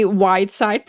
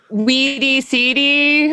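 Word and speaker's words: Wydsyd: I had to spell it weird, so everybody throws everybody off, but it's W-Y-D-S-Y-D. Thanks for saying it right. – What's the worst pronunciation you've heard? – Weedy CD, I think.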